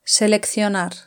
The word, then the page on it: seleccionar: Spain Spanish